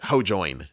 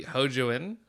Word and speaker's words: Hojoin: Everyone in the English dub pronounce it Hojoin, like ho and then join, like you join a conversation.